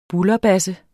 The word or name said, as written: bulderbasse